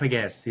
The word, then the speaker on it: Pegues: Mike Pegues, yeah. – Pegues, yeah, sorry. But I mean, like, what? – Pegues, that's how you say it.